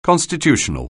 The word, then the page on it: constitutional: anglais